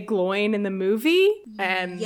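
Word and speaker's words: Glóin: But it's, I think, technically supposed to be pronounced Gloin because there's an accent on the I, and there's been a lot of back and forth.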